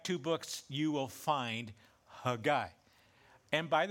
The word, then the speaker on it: Haggai: We typically have referred to it as Haggai. It's Hebrew, of course, and so I, through this message, am going to refer to his name in the Hebrew pronunciation, which is Haggai, okay?